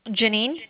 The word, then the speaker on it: Jeanneen: Janine. – Can you spell it? – J-E-A-N-N-E-E-N. – You said J-E-A-N-N-E-E-N? – Correct.